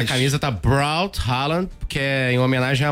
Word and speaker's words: Haaland: Como é que fala? É Haaland. Haaland, é, na verdade, a FIFA divulgou um vídeo em que os jogadores, eles dizem a pronúncia e ele fala Haaland. – Haaland. – Quase fica uma letra O, né? – Haaland.